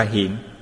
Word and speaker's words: hiện: Chữ kiến này đọc là hiện, chính là hiện của hiện tượng.